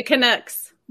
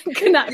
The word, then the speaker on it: Canucks: The Canucks. – The Canucks are in the house. Canucks. – Yes. I said that wrong. Canucks.